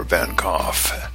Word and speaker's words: Van Gogh: One such activity was entering a dark theater on Friday afternoon and emerging full of the wonder of Van Gogh, or Van Gogh, as we should be pronouncing it.